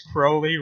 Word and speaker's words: Crowley: It's Crowley rhymes with Holy.